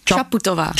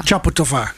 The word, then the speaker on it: Čaputová: En ook, jij spreekt het anders uit dan ik. Ik wou zeggen Čaputová, maar Čaputová. – Čaputová, klemtoon. – Op de eerste.